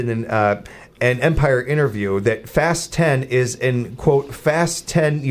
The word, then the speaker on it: Fast X: It's not Fast X. It's pronounced Fast 10, not Fast X.